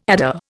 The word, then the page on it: adder: Indian